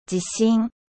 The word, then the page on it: séisme: French